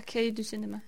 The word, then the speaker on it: Cahiers du Cinéma: Og instruktørerne havde jo sådan deres afsæt i kritikermiljøet på Cahiers du Cinéma. Udtaler jeg det rigtigt? – Ja, Cahiers du Cinéma.